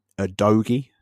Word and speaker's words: Udogie: They've got on coming back, they've got Sessegnon, and they've got um, Destiny Udogie. That's how you say his name.